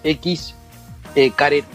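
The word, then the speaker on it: Xcaret: Lo pronuncio como puedo, ¿no? Es X. Caret.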